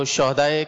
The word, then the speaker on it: شہدا: یہ صحیح تلفظ ہے شہدا، صحیح. – ہاں پر زبر آئے گا، شہید کی جمع ہے شہدا. شہدا ہاں کو ساکن پڑھنا جو ہے نا، یہ اس کے معنی بدل جاتے ہیں.